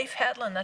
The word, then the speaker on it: Leif Hetland: Leif Hetland, that's right.